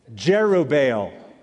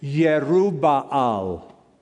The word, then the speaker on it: Jerubbaal: Jerubbaal, or the Hebrew pronunciation, Yerubbaal. Yerubbaal. I'm not going to even pronounce it that way. We're Americans, so we're going to say Jerubbaal.